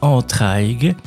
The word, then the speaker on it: Entraigues: Un autre endroit que j'aime beaucoup, c'est le village, on écrit Entraigues, on prononce Entraigues.